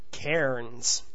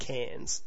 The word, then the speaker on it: Cairns: He insisted it should be pronounced just as it's written, Cairns. But in reality, it's more like Cairns, as in cans of beer.